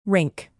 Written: rink

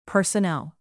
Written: personnel

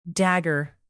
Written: dagger

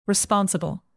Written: responsible

